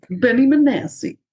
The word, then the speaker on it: Benassi: Benassi? Oh, my God. I just butchered his name. – Benny Benassi.